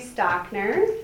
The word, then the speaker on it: Stockner: Cassie, say your last name correctly. – Yes, so my name is Cassie Stockner.